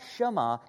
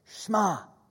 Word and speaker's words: shema: And in Hebrew, when a word like this is a command or an imperative, it shortens. The middle vowel shortens. So it's not shema. It's shema.